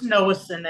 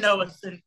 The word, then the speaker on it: Nowocin: How is it pronounced? – It's Nowocin, Ed. Cody Nowocin, yes, sir.